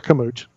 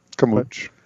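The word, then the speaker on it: Carmouche: Your title main event, Valentina Shevchenko versus Liz Camucci. – Or Camuch. – Camuch. The E's pronounced different in different countries.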